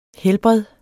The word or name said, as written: helbred